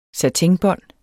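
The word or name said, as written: satinbånd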